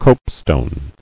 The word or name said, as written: Copestone